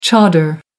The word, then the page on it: chador: American English